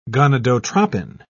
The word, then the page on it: gonadotrophin: British English